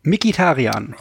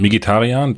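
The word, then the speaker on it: Mkhitaryan: Und äh, heute kam mir der Fall hoch ähm, mit äh, Henrik Mkitarian, wird er so, wie wird er ausgesprochen? Mikitarian. Ähm, Mikitarian, Dankeschön,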